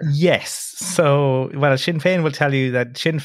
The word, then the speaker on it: Sinn Féin: And Sinn Féin, I'm saying that correctly, right? – Mm-hmm. You are indeed.